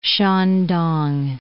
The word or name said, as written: Shandong